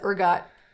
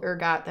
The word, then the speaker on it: ergot: So that is the ergot poisoning theory, which also came out in the 70s. But basically their theory is that there was this fungus called ergot that was... – Can you spell that? – E-R-G-O-T.